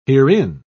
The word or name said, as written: herein